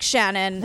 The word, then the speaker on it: Shanann: It's not spelled like Shanann. – Right. – But everyone else says Shanann. So I even wrote it not like Shanann because I was like, brain, ADHD brain, you're going to say, you have to write it phonetically. So I'm going to go with Shanann because that's what, it's weird because it's what everyone calls her.